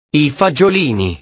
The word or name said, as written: i fagiolini